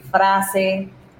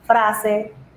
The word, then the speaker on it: Fraze: That's FRAZE Online. No spaces, all of that. Se puede decir frase. People don't usually know how to say my name. So Fraze or frase, la cosa.